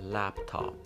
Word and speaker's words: laptop: پس صحیحش قاعدتا باید باشه تاپ. یعنی هر دو حرفی که به صورت ب تلفظ شده و تا حدی هم جا افتاده بین بعضی از ماها باید تبدیل بشه به پ یعنی به همون چیزی که وجود داره هست لپتاپ لپتاپ لپتاپ